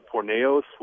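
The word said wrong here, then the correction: porneia: which means fornication. – Pornea, yeah. Mm-hmm. – Pornea.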